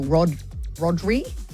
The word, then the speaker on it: Rodri: Firstly, because Barcelona has just signed Spanish midfielder Maestro Rodri.